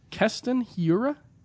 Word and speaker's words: Keston Hiura: Keston Hiura? Am I pronouncing that correctly? – Sounds right to me.